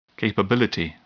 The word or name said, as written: capability